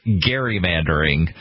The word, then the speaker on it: gerrymandering: And it should actually be pronounced gerrymandering because it was named after Elbridge Gerry.